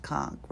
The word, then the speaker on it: conch: It's got to be pronounced conch, right? Even though it's C-O-N-C-H.